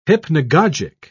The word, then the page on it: hypnagogic: British English